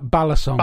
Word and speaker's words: balisong: is it bay long is the correct term from that, and butterfly is the anglicized term, I think? – Uh, it's ba- balisong. – Balisong. Okay, I just didn't know the pronunciation.